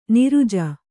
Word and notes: ನಿರುಜ: kannada

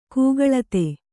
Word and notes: ಕೂಗಳತೆ: kūgaḷate

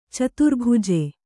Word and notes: ಚತುರ್ಭುಜ: caturbhuja

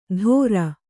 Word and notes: ಥೋರ: Kannada

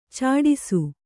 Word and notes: ಚಟ್ಟಿಸು: Kannada

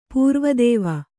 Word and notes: ಪೂರ್ವದೇವ: pūrva dēva

